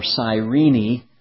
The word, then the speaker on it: Cyrene: And there were Cyrenians, it says, first of all, from Cyrene or Cyrene, if you follow Scorby's pronunciation.